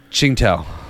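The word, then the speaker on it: Tsingtao: Qingtao. – Qingtao.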